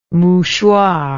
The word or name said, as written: mouchoir